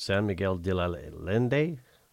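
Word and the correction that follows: San Miguel de Allende: De Allende. – De Allende. – Yes, De Allende.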